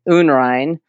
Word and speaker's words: Unrein: And it is actually pronounced in German, Unrein.